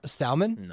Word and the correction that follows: Strowman: No. Strowman. There's an R in there. – Strawman? – No, Stroh.